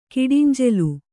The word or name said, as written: ಕಿಡಿಂಜೆಲು